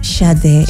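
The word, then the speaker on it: Sade: ¿Cómo se pronuncia? Yo digo Shade. – Yo le digo Shade. – ¿Shade? – Shade. Es que más decía suena mejor. Shade.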